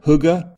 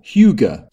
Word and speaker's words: hygge: OED reliably informs us that we can go for hygge or hygge.